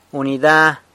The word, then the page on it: unida: unit